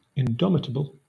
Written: indomitable

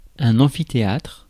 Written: amphithéâtre

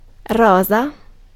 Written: romantic

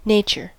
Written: nature

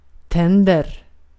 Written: tänder

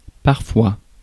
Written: parfois

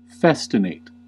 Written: festinate

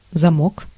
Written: замок

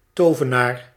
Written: wizard

